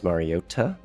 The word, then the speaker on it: Mariota: the way I pronounce Mariota with an emphasis on the T, and so much more.